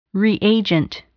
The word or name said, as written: reagent